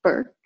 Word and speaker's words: Burke: Burke, although my artist name is just Shelita.